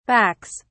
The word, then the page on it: backs: British English